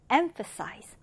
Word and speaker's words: emphasize: 자, 이 단어 첫 음절에 일강세를 두고요. 그리고 여기 ph는 f 발음으로 해줍니다. 그래서 emphasize. emphasize는 뭐냐면, 자, 이렇게 강조하다라는 뜻이에요.